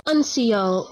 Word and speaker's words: uncial: And is that how you pronounce it? – That is how you pronounce it, because okay. I had to look it up. – Okay, I looked up the definition. I didn't look up the pronunciation. – I went to several robots to tell me how to pronounce it, and they all pronounce it as uncial. – Really? – Yeah. – Okay, not like Unicial or Unicial something? – No. So it's uncial.